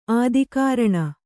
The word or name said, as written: ಆದಿಕಾರಣ